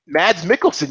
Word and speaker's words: Mads Mikkelsen: Mads Mikkelsen, yes.